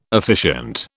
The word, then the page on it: officiant: British English